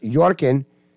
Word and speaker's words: Joachim: Now, the Harry and Meghan of Denmark, Prince Joachim, pronounced Jorkin, Prince Jorkin, is spelled Joachim, though, Jorkin. I just want you to know that.